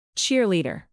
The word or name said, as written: cheerleader